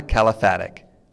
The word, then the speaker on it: Kalafatic: My next guest is Ivana Kalafatic.